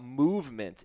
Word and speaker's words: MVMT: I'm very excited to tell you about Movement. It's spelled MVMT when I give you the website.